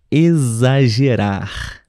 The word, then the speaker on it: exagerar: Pessoal, temos a palavra exagerar, exagerar, mas esse exagerar não se escreve com Z não se escreve com S. – Tampouco com S também. Se escreve com X.